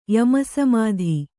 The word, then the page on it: ಯಮಸಮಾಧಿ: Kannada